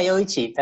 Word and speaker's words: Yoichi: Yoichi, and am I pronouncing it right? – Yeah, Yoichi, that's fine.